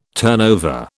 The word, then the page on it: turnover: British English